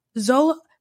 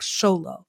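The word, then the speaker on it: Xolo: Zolo, Okay, so phonetically, if I'm looking at his name, I would say Zolo with that Z. But when I hear him say his name, it sounds like Sholo.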